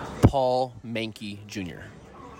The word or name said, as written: PAUL MENCKE JR